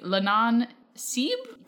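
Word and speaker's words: Leannán Sí: So we're going to talk about the Lannan Seeb, probably is how you pronounce it. – Yes, that sounds right.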